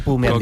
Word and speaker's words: Bu: Yun Chaukete Bu. – Se pronuncia Pu.